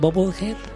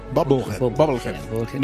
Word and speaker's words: Bubblehead: Sí, pronuncié bien. Bubblehead. – Bubblehead. – Bien.